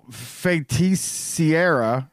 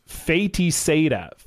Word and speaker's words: Feiticeira: It's a witch or a sorceress in Portuguese, and it is pronounced. It's pronounced Feiticeira.